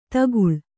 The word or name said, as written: ತಗ್ಗಾಳು